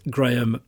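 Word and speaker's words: Graeme: It's at Graham Dogfather. And my name is spelled G-R-A-E-M.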